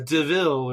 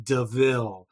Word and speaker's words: De Vil: I'm Cruella DeVille or something, and he like points at the car and is like, yeah, it says devil, but it's pronounced DeVille.